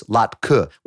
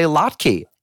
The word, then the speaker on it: latke: The only pronunciation I'd ever heard was latke, with a schwa, a little With a little zhuzh there. – A little vowel there.